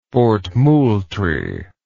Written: fort moultrie

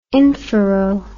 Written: infero

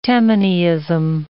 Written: Tammanyism

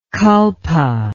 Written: kalpa

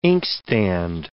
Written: inkstand

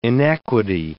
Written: inequity

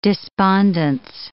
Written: despondence

